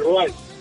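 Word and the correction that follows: Rouen: En ¿cómo se llama? Ruén. ¿Y eso está? – Ruén. Sí, ay, lo pronuncia bien.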